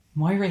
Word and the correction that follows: Maura: It's Maura. – Maura. – Okay, Maura. Sorry.